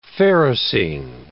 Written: ferrocene

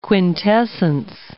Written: quintessence

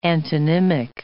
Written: antonymic

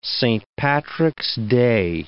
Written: Saint Patrick's Day

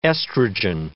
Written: estrogen

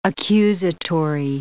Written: accusatory

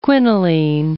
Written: quinoline

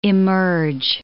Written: immerge

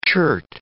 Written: chert

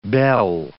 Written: bel

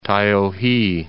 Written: Taiohae

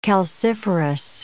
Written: calciferous

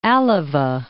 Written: Alava, Cape

